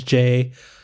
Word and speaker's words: KJ: I'm KJ Miller on all the things, but my name is Jay. The K is silent.